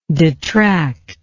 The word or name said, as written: detractingly